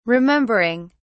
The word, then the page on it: remembering: British English